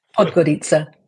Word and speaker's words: Podgorica: Podgorica, yes? – Podgorica, yes.